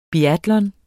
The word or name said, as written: BIATLON